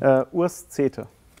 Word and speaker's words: Urs Cete: It's a German pronunciation. – Urs Cete.